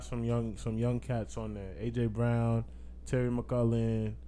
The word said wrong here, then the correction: McLaurin: if that's how you say his name, McLaurin.